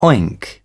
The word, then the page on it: oink: British English